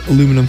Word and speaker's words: aluminium: Tin foil, aluminum foil, aluminium? – That's. – Yep. – Is not a word. – Is too. I'm pretty sure we do just add in an extra syllable.